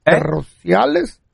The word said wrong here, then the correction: torrenciales: ¿Terrenales? – No. Torrenciales.